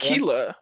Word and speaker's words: Akila: So unfortunately, Artemis had to help Diana, you know, kill Akalia. I just realized I'm saying her name wrong. I apologize. – I think Akalia. It's Akila. Is it Akila? Akalia? Akila? – A-K-I-L-A.